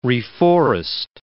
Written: reforest

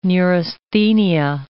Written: neurasthenia

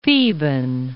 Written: Theban

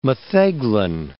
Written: metheglin

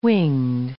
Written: winged

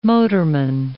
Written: motorman

